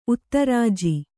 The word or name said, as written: ಉತ್ತರಾಜಿ